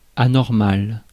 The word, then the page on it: anormal: France; [a.nɔʁ.mal]